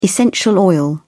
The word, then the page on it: essential oil: British English